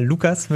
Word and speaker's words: Lucas: Ja, Lücke habe ich ihn immer ausgesprochen. Er selbst dachte, dann mal Lukas möchte er gerne genannt werden.